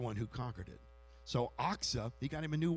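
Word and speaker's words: Achsah: And so here's Caleb, and he said, I tell you what, I'm going to give my daughter, Aksa is the way you pronounce it, Aksa in marriage to the one who attacks and captures Kirith Sepher.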